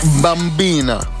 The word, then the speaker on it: bambina: Bambina.